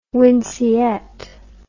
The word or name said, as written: winceyette